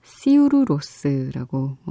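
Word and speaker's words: Sigur Rós: 밴드의 이름인 시규로스는이 밴드가 결성된 날에 태어난 윤 씨의 여동생 이름이 바로 시규로스 승리의 장미라는 뜻의 바로 이 이름에서 따왔다고 합니다. 근데 이 아이슬란드의 발음으로는 시우루로스라고 어, 하죠.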